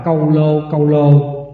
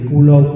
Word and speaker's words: câu lô: Đúng là chúng ta phải đọc câu lô, chứ không có đọc cái chữ Kulo Kulo, tại vì đây là phiên âm theo kiểu xưa đó.